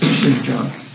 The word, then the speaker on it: Shinjan: I spelled it differently than here, so you can see how it's pronounced, Shinjan. Suppleness,